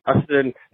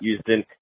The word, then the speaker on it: Ustin: I know it's spelled U-S-T-I-N, but it's actually Ustin, not Ustin. It's Ustin in with a D in the middle.